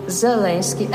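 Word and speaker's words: Zełenski: Zeleński. L, nie L. – Польське «Л», воно м'якіше.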